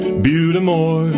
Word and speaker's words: Butte des Morts: There's a lake that looks like Butte des Morts, but it's really Butte-a-more.